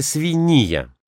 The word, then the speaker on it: свинья: Кстати, свинья, а не свинья. Опять загадка. И опять никакой загадки. Старое ударение в этом слове «свинья».